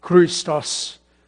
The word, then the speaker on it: Christus: I'm not going to tell you how the alphabet in the Greek goes, but it's C-H-R-I-S-T-U-S. But it's pronounced in the Greek. It would have been pronounced Christos as well. Christos, Christus.